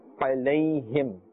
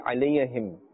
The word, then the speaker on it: عليهم: عليهم. Be careful not to say alayahim.